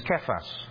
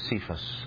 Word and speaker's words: Kephas: And we also know his Aramaic version of Petros, which is Kephas. Often mispronounced as Cephas.